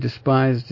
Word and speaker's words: despised: Actually, that word despised is pronounced despised, as though the E is like a letter I, short vowel, despised, like D-I-S, dis.